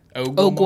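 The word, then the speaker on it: Ogua: And it's not spelled like Ogwa as in like water in Spanish. It's O-G-U-A. – Ogwa? – Ogwa monster.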